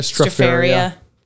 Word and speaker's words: Stropharia: The Philly had me interested because it was featuring local mushrooms from a mushroom farm, Stropharia. I hope I'm not mispronouncing that. – Stropharia.